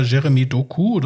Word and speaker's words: Jeremy Doku: Und zwar geht es um, sagt ja, belgische Nationalmannschaft, in dem Fall konkret um den Spieler Jeremy Doku wird ausgesprochen.